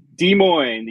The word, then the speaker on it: Des Moines: I think Des Moines, the S is silent.